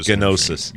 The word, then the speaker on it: Gnosis: Gnosis. – Yeah. – I know the G is silent, but it's just easier for people to see it.